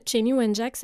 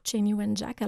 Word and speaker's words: Chanie Wenjack: Cheney Wenjack, ça s'écrit C-H-A-N-I-E, W-E-N-J-A-C-K. Donc, Cheney Wenjack.